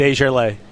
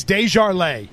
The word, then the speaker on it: Desjardins: And earlier we were trying to nail down a pronunciation on Patriots right guard. – Desjardins. – So it's Desjardins. – I believe that's the way he pronounces it.